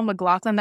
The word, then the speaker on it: MacLachlan: I mean, yeah, I feel like Kyle MacLachlan, that's how you say his name, right? – Yeah.